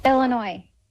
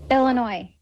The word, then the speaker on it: Illinois: Él, español, ella estadounidense, concretamente de Illinois, y fue quien grabó este audio para que escuchemos cómo se dice realmente. – Aquí te doy la vuestra. – Illinois. – Vamos a poner la parte corta. – Illinois. – No Illinois. Bien.